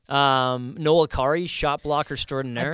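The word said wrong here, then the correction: Acciari: I believe it's pronounced Achari.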